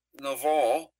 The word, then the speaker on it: Nabal: Nabal, it should usually be pronounced with a V sound instead of a B.